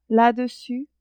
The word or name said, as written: la-dessus